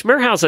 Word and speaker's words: Merhow: which Merhow's a, I don't even know how you say it. It's M-E-R-H-O-W.